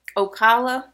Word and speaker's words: Ocala: Ocala, Florida.